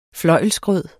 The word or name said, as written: fløjlsgrød